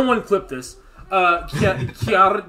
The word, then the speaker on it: Cœur: You pronounce it Cœur. – Cœur? Cœur de Pirate? – Use your throat. Cœur. – Cœur de Pirate. Ah, god damn it. – You can roll your R.